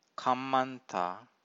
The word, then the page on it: kammanta: Pali